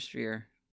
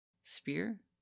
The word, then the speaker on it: sphere: And then he's visited by Mr. Sphere. I don't know why I didn't know until today that I don't know how to say the word sphere. Spear? – Sphere.